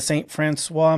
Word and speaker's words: St. Francois: And I'm going to say it the right way. I know there's other ways of pronouncing this, but the St. Francois Mountains of Missouri.